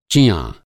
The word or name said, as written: चिँआँ